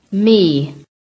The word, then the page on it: ME: American English